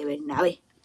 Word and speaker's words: Bernabé: Antonio Valero de Bernabé. Con acento en la última E.